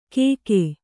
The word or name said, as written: ಕೇಕೇ